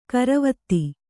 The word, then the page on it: ಗೊರವಿತಿ: goraviti